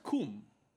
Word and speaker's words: cum: To be fair, also it's pronounced cum. – Yeah. – Like, oh, is it? – It's like summa, it's not, cum laude.